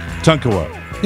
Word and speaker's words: Tonkawa: Have I been pronouncing that one all wrong all week? – I've gotten five different pronouncers. I'm going to go with the one that I gave you because that's the person who submitted it. – That would be Tonkawa, Oklahoma.